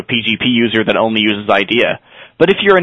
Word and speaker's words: IDEA: Is that pronounced IDEA or IDEA? – I assume it's IDEA or IDEA. Yeah. It's IDEA. It's all in capitals, but we're going to call it IDEA.